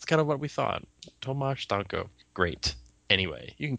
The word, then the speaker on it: Tomasz: It's pronounced Tomasz.